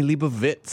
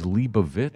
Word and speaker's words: Leibovitz: Correct pronunciation, Annie Leibovitz. – Oh, no. – And I'm like, what? You just fucked my charity, man. – He didn't do that. – He did. – The correct pronunciation is Leibovitz.